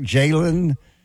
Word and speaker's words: Jalen: Is it Jalen and it's spelled J-A-L-E-N. Is that correct? Jalen? – Yeah. Jalen Daniels, not to be confused.